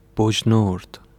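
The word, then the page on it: بجنورد: Bojnūrd; Persian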